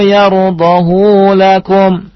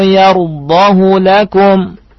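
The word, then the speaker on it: yardahu lakum (يرضه): Ya, antum tidak boleh baca wa in tashkuru yardahu lakum. Enak karena panjang, tapi riwayatnya adalah bacanya pendek. Ya, wa in tashkuru yardahu lakum.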